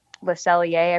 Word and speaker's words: Le Cellier: On Thursday, when we go to Epcot, we have lunch at Le Cellier. I probably said that wrong, but no, you did. It's correct.